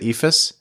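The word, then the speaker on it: eephus: Is that how you pronounce it? Ephus? – Yeah.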